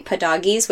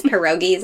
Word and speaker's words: pierogies: And then rumaki and what we say padoggies, which is the not correct pronunciation of it, but my grandma called them padoggies. It's pierogies, I think.